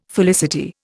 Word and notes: felicity: Indian

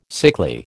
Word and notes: sickly: American English

